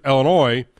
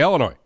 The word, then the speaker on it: Illinois: And the state of Illinois, not only is, first off, you can't pronounce the S at the end, right? We all know that you can't say Illinois, right? Everybody understands the S is silent.